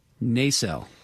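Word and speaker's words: Naselle: The pronunciation guide published by WSU in the 1960s puts the emphasis on the second syllable, Nacelle. – Yeah. – But when I called about six different businesses there yesterday, the consensus was the opposite.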